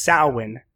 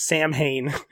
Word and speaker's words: Samhain: And you have mispronounced it forever in your head. Samhain. That's Samhain, not Samhain.